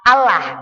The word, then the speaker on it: Allah: Ya, di situ diajarkan kalau ketemu lafadz Allah sebelumnya fathah atau dhammah, maka harus dibaca tebal. Ya, Rasulullah. Ya, Allah. Kalau nggak dibaca tebal gimana? Allah. Ya, nulis Indonesia A-L-L-A-H. Allah bacanya. Tapi karena kena ilmu tajwid, bahasa Arab jadinya Allah.